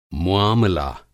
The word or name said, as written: मुआमला